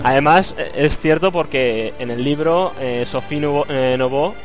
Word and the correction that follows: Neveu: ¿se pronuncia así? ¿Cómo se pronuncia? – Eso Javi más que yo. – Javi. – Neveu. Pero bueno. – Sophie Neveu, ¿no?